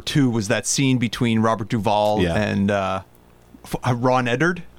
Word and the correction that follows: Eldard: Ron Eldard. Which I think I'm pronouncing that A very hard. – Okay. – Probably just like Ron Eldard.